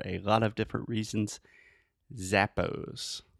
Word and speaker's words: Zappos: when we say Zappos, Zappos. – This is a very good example of at least American, North American English pronunciation. We say za, a.